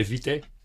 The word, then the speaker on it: Vitae: Bom, na verdade é Vité. – Muito bem. – A palavra vem do latim, é uma palavra do latim, e lê-se exatamente como pronuncia em latim.